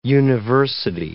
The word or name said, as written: university